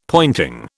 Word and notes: pointing: British English

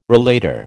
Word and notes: relater: American English

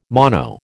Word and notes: mono: American English

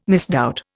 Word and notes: misdoubt: Indian

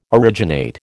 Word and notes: originate: American English

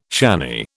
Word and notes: shanny: British English

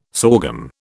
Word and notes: sorghum: British English

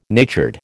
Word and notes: natured: American English

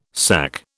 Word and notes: SAC: British English

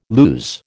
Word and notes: lues: American English

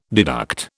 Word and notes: deduct: British English

